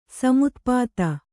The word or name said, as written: ಸಮುತ್ಪಾತ